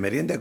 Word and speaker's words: merienda: How do I say that? Merienda? – Merienda. – Oh, I will say that.